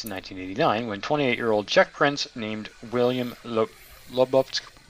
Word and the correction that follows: Lobkowitz: Yeah, I'm going to butcher his name. Lobkowitz. – Lobkowitz. – Lobkowitz, yes.